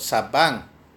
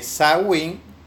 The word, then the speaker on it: Samhain: Los orígenes de Halloween se cree que contienen muchos de los remanentes de un feriado de otoño celebrado por los druidas, llamado Samhain, aparentemente pronunciado Sabban, aunque algunos dicen que Samhain o Samhain y aparentemente significa fin de verano.